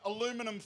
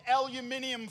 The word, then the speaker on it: aluminium: Somebody down here said aluminum foil. It's aluminium foil. So if you want to learn how to say it properly, you say aluminium foil.